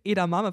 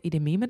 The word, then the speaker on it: Edamame: Du aus Versehen Edamame falsch gesagt hast. Wollen wir noch eine Runde Edememe zustellen? Und haben wir so oft dann die Tage gesagt, Edememe.